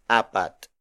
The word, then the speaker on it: apat: Meanwhile, the Tagalog word for four is apat.